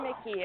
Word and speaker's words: Mickey: It's Kendra Mickey. It's pronounced like Mickey Mouse.